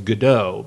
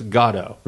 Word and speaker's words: Godot: Godot. Yes. – It's mispronounced within the show as Godot by Pozzo, but it's Godot.